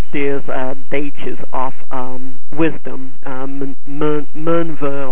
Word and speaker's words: Minerva: It's apparently pronounced the same way that Minerva is pronounced from Roman mythology.